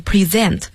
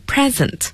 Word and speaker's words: present: 大家要注意一下这个单词的发音，它做动词所以要读成 present，如果做名词的话读成 present，我们叫礼物。要注意动词 present，名词 present。